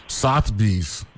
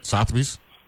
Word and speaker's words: Sotheby's: Sotheby's. That's how you pronounce it, right? Sotheby's.